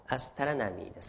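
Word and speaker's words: astronomy: 発音はアスタラナミーですけどね。スペリング的にはね、アストロノミーですよね。アストロノミーですね。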